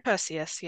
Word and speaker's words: Perseus: It's Perseus, right? – Perseus, yeah.